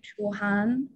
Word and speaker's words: Chuhan: and she called me like Chuan, like it's a fast way to say my name like Chuhan, and say when you speak it like faster, and it may, it may sound like Chuan, uh-huh.